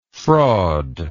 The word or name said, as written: fraudfully